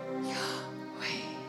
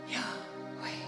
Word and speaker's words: Yahweh: And it's pronounced like this. Yah. So, the reality is, every breath you take is saying the name of God. Yahweh. Yahweh. When